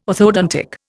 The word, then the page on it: orthodontics: Indian